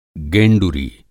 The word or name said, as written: गेंडुरी